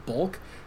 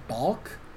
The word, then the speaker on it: Balk: Also, I'm saying her name wrong. I keep saying Bulk. I think it's Balk. Like when a pitcher...